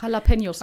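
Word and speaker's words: Jalapeños: Jalapenos.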